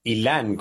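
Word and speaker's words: Ilán: Ilan, con acento en la A.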